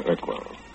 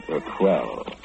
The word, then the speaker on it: Urquell: He was drinking beer, Pilsner Urquell. although he mispronounced it, he called it a quell.